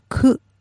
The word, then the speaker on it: ku: So, and nine is also sometimes pronounced ku.